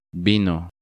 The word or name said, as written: Vino